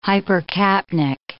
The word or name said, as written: hypercapnic